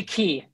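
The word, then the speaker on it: Ke: call me Key. – Uh, yeah, it's, it's pronounced Key like a like a key to the door.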